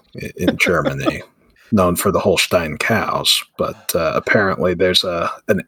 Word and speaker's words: Holstein: Holstein. – Okay. There we go. – Holstein. All right. It's actually Holstein.